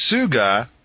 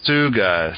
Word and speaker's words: Tsuga: I always kind of think smells like, and then suga, which is spelled T-S-U-G-A. – Right. – Suga. So the T is sort of silent.